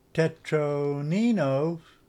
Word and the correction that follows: Tetromino: Let me pronounce that right. My pronunciation is absolutely the worst. If I don't know you and I don't actually talk to you every day, my pronunciation is awful. Yeah, Tetromino. Yep.